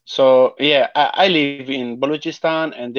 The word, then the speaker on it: Balochistan: I was going to say, so you were born in Pakistan, and you were born, how do you say it? Balakistan? – Balochistan, yeah.